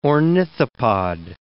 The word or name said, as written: ornithopod